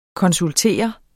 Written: konsultere

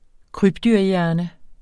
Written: krybdyrhjerne

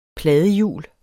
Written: pladehjul

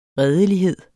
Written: redelighed

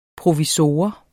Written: provisor

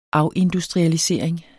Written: afindustrialisering

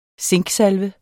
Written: zinksalve

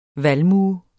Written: valmue

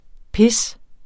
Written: pis

